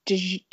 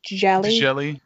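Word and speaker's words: Djèlí: I read, are you familiar with P, I don't know how to say his first name, Djali? – Djeli, I think it's Djeli, Djeli Clark.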